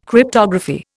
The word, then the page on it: Cryptography: Indian